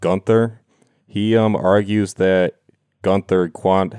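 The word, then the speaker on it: Quandt: How do you say the Quant family? – Quant? Yeah, Quant. Yeah. – Okay. I got it right. I have Quant. – It's like quantitative. Quant.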